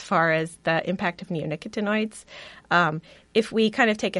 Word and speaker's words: neonicotinoids: Neonicotinoids.